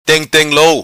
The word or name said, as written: Teng teng lou